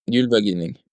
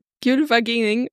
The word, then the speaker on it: Gylfaginning: Sie ist aus der Gylfaginning. Ist das richtig ausgesprochen? – Gylfaginning. – Gylfaginning, Gesang 49.